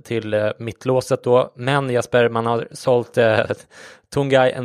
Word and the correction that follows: Tanguy: Ndombele till Tottenham. – Tanguy. – Ja, du kan få ta det uttalet här. – Nej, jag vet bara att det är Tanguy Ndombele.